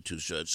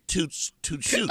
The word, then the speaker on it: Too Short: But the guy, this English guy, had probably never heard of Too Short, so he was pronouncing his name as Toot Shoot or something like that.